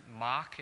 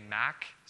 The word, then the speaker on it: MAC: Yeah, so the MAC program, we pronounce it MOC. In the UK, they say MAC, so I don't know how they pronounce it in other places.